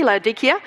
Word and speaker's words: Laodicea: That's actually how they say it in Turkey, Laodicea, because they don't have a soft C in the Turkish language.